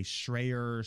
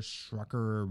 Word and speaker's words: Schreier: Why does everybody get Jason Schreier's name wrong? They say Schreier, Schrucker, whatever. Schreier.